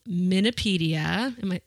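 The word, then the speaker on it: MNopedia: She is the editor of the Digital Encyclopedia of Minnesota History called Minipedia. I am saying that right. – Okay. You got it just right.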